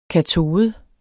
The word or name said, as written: katode